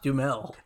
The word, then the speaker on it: Duhamel: Um, personally, Fergie marries a guy named Josh Duhamel. Am I pronouncing that right? – No, no, no. Josh Duhamel. It's just it, it's just Josh Dumel.